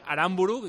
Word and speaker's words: Aramburu: Hay oyentes a los que le sigue sonando raro ese Aramburu. Y a mí también. – Que Aramburu sí, sí. No lleva tilde y que no digáis cosas de idioma venezolano y demás.